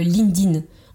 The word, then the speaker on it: LinkedIn: on prononce euh, LinkedIn. On oublie le, le, KE e, en fait. Donc c'est LinkedIn ou LinkedIn éventuellement, avec plutôt un T. Mais on oublie le KE.